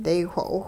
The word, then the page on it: daywho'owh: Hupa